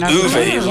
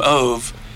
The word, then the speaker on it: Ove: So that's so if you watch the first movie they did in the original language, and so it was Uwe, was the way they pronounced it, and then we call him Ove, and I don't know, I haven't read why they changed it to Otto.